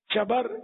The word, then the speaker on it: شبر: اعلیٰ حضرت نے اس کا تلفظ ہی بیان کیا, شبر, شبیر, مشبر.